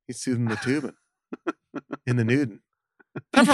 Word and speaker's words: Toobin: I don't know why it's funny that Tubin is spelled T-O-O-B-I-N. – Hilarious name. – It's Tubin.